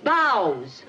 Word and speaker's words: bough: That's boughs. – B-O-U-G-H is bough? – Right.